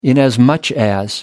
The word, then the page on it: inasmuch as: American English